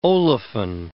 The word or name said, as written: olefin